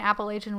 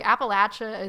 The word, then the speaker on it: Appalachia: Tell if like the reason why I say apple, why I grew up saying Appalachian was because that's how the North says it, but it's because like Appalachia is like more of a mid-Atlantic pronunciation. I feel like, but I could be completely making that up. But the most kind of widely accepted correct pronunciation is Appalachia.